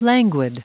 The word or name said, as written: languid